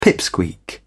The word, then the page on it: pipsqueak: British English